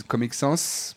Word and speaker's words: Comic Sans: Je crois que les Américains, enfin les Anglo-Saxons disent Comic Sans parce qu'ils prononcent les S à la fin des mots.